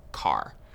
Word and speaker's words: char: Chars. So chars are used for variables that will store single characters. Char is short for character, and sometimes you might hear people pronounce it as car.